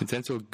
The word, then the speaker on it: Vincenzo: Oder dann auch ähm, Lorenz Frage an Abraham zur Verbindung mit Vin Vincento, Vincenzo, boah, meine Güte, ich kann es gar nicht richtig aussprechen. Boah. – Vincenzo Griffo.